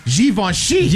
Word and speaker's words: Givenchy: I get, you're supposed to pronounce it with a Z, according to this. It's Z-H-E-E-V-O-N-S-H-E-E. Givenchy. – Givenchy.